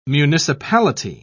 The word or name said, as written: municipality